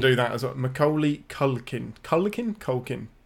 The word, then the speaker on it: Macaulay Culkin: Say his name again. Because I think you've pronounced it differently every time you said it. – Macaulay Culkin? – Perfect.